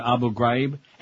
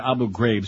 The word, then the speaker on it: Abu Ghraib: Seymour Hersh, you know, the New Yorker article, how the Department of Defense mishandled the disaster at Abu Ghraib. And now they're calling it Abu Ghraib, so I'll say it too, but that's not how you pronounce it. Abu Ghraib.